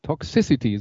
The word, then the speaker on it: Toxicity: System of a Down, Down und der Song, da muss ich jetzt aufpassen, dass ich es richtig ausspreche, Toxicity. Toxicity, so rum.